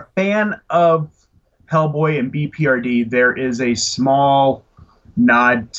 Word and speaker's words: BPRD: I collected BRP or what. – BPRD. – BPRD. Did I say that right? Okay. I was like BRPD. That isn't right.